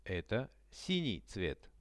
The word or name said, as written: eto sinij